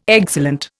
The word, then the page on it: excellent: Indian